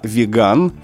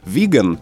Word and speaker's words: веган: в том числе и слова «веган», «веганский» с ударением именно на втором слоге.